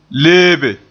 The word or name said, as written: leebe